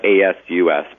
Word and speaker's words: Asus: I'm thinking of, in particular, the Asus Transformer Book T100, and that's ASUS, but it's pronounced Asus.